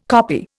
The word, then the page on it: copy: Indian